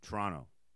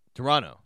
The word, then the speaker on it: Toronto: And if you're there, you don't pronounce the second T. So Toronto. – Toronto. – It's a high school in Toronto. – Really? – Yeah, that's how you say it.